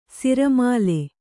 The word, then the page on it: ಸಿರಮಾಲೆ: siramāle